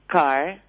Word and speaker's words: Kerr: Te aconsejo uno que se llama Philip Carr, pero se escribe K-E-R-R.